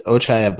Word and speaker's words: Ochai: Ochi Agbaji. How do you say the last name? – It's Ochai Agbaji.